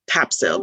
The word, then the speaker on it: Topsail: So it's spelled T-O-P-S-A-I-L. You would think it's Topsail, but it's pronounced Topsail.